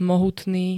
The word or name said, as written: mohutný